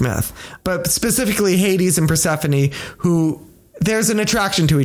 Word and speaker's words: Persephone: Persephone.